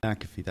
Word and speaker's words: McAfee: McAfee. – McAfee, that's it.